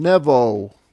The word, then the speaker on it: Nebo: Nevi'im comes from Nevo, which is the literal pronunciation of Nebo in the Hebrew. It means prophet. He is equivalent to Hermes in Greek, H-E-R-M-E-S, and Mercury, the planet Mercury in Roman theology.